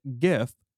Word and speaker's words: GIF: So Shoujo Roboto actually made an animated GIF. Did you hear that? That's a hard G. GIF.